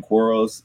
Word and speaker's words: Quarles: So my website, Jonathan Quarles, last name is Q-U-A-R-L-E-S. And it's Jonathan is spelled the way the biblical name, J-O-N-A-T-H-A-N.